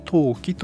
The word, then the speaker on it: 登記: はい、漢字で書きますと、登録の登に記録の記で登記と読みます。